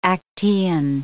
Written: Actaeon

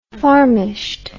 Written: farmisht

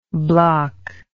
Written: blockable

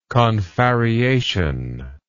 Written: confarreated

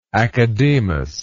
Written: academus

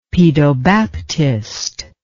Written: pedobaptist

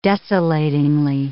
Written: desolatingly

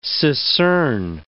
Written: secern

